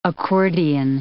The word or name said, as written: accordion